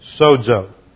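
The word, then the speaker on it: sozo (σώζω): In the Greek, S-O-Z-O, long O, sozo.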